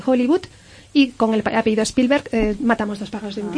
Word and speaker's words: Spielberg: ¿Qué significa Spielberg? No sé si lo digo bien. – Sí, sí, sí, lo dices perfectamente.